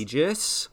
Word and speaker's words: Aegis: Oh, it's Aegis. – Is it Aegis? – Yeah. – Oh, my gosh. – I mean, according to Google, American pronunciation.